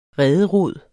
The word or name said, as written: rederod